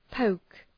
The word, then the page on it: poke: pəʋk